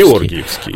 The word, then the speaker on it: Георгиевский: Кстати, многие говорят Георгиевский, как правильно? – На польский манер. – На польский Георгиевский? – Нет, я думаю, что правильно говорить все-таки на русский манер Георгиевский. – Георгиевский.